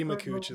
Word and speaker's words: Makuch: I'm reading off the GameSpot article by Eddie Makuch? – That's Eddie Makuch is how I would pronounce it, but yeah.